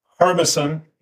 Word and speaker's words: Harbison: One is Harbison, H-A-R-B-I-S-O-N. – Repeat that again, please. – Harbison. – Yep. Spell it, please. – H-A-R-B-I-S-O-N. – B-I as in boy? – Yeah. – Okay. Harbison. – Harbison, gotcha. – Right. The second one is Oma. O-M-A.